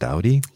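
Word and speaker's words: Doughty: name is pronounced Dowdy.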